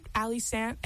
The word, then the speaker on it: Allie Sandt: Yeah, if you look up Ali Sant, A-L-L-I-E-S-A-N-D-T, it rhymes even better.